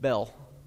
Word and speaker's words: Bel: Now I say Baal not to say, oh look, I'm pronouncing his name correctly. No, I'm saying Baal because there's actually another god named Bel. B-E-L. So when we say Bel, I don't want to confuse it with Bel, because I'm going to mention Bel, B-E-L, and Baal, B-A-A-L.